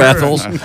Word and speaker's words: Athol: A-T-H-O-L.